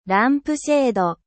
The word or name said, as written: abat-jour